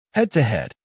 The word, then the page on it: head to head: American English